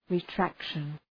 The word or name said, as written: retraction